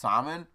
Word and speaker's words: Samhain: It's pronounced Samhain, but it's spelled Samhain.